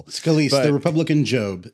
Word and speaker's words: Scalise: that's how you say his name, Scalise, was no longer viable because Trump yelled about him having blood cancer.